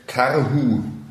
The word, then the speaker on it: Karhu: Karhu. Also deutsche Aussprache natürlich, ne? Damit, damit ihr euch denken könnt, wie man schreibt.